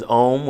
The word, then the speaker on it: OM: That's why I pronounce it that way and not just OM as an acronym because it sounds better. I mean, so from a branding perspective, it sounds better to say OHM than it does to say OM.